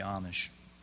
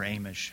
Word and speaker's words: Amish: And I've always been intrigued by the Amish, or Amish, however you like to pronounce it.